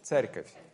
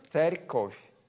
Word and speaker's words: церковь: Церковь. – Huh? – Церковь. – Церковь. – Церковь. – That's too hard to pronounce. – Yeah, церковь. Like TS, церковь.